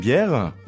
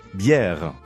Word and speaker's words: bière: Bière. – You can hear there's two vowel sounds in there. – Bière. Bière. – Bière. You say them real quick together.